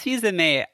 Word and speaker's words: Fils-Aimé: I started saying Fils-Aimé, and apparently it's Fils-Aimé. – It's Fils-Aimé, and I only know that because I have like a latent knowledge of French being Canadian.